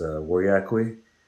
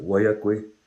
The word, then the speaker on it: Weryackwe: My last name is Weryackwe. Comanche, I guess, kind of pronounce it Weryackwe.